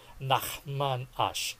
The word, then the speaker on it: Nachman Ash: Ay ah, si Nachman Ash. Tama ba? Ganun ba ang pag-pronounce? – Nachman Ash.